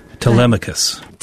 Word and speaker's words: Telemachus: Telemachus. – Telemachus.